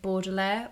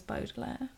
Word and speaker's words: Baudelaire: I was pronouncing it Baudelaire Orphans, and then I watched the Jim Carrey film and found out it was Baudelaire.